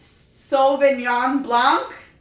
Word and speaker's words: Sauvignon Blanc: But I believe it's a Sauvignon Blanc. – You got it right.